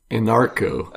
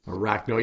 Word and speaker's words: Anarcho: Or Arachno, or how you pronounce it? – Anarcho. – Anarcho. Yes. There you go. – Okay, yeah, okay. – Arachno.